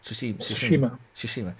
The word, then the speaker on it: Tsushima: Tsushima, am I saying that right? – Tsushima.